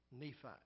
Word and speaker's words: Nephi: The first book, I think it's pronounced Nephi, N-E-P-H-I.